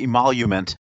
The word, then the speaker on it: emolument: And the issue and the word is emolument. Am I saying that right? – They usually say emolument.